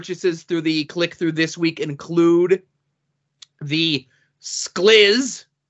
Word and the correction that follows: SKLZ: Um, that's how I you would pronounce S K L Z. – Skills. Zip. – Oh, what? Skills.